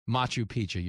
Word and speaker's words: Machu Picchu: Surprise, I'm in Peru and I'm at Machu Picchu. Did I say that right? I'm not cultured. Machu Picchu. Machu Picchu, you're right.